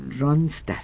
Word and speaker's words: Ronstadt: Ronstadt.